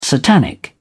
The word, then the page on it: satanic: British English